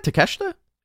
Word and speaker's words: Takeshita: Did I say it right? Takeshita? – Yeah, you said it right.